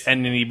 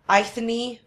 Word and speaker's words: Eithne: It's Enya, but it's spelled E-I-T-H-N-E, right? – Yes. – Enya Brennan. Yeah. But her name in Gaelic is pronounced Enya, but it's spelled F. – Oh my god! If I were to just pronounce it the way it's spelled, Ithne.